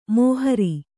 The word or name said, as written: ಮೋಹರಿ